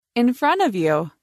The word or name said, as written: In front of you